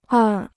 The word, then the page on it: 二: èr